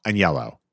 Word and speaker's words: Aniello: Aniello. Is that wrong? – That's right.